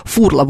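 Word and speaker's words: Furla: Так, шеф-комендор продолжает со мной спорить, значит, по поводу ударения. Я специально, шеф-комендор, итальянскую рекламу, итальянскую послушала. Вот итальянцы в Италии говорят, что она фурла.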